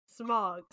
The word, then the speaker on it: Smaug: Smaug. – Smaug, it's pronounced. Smaug.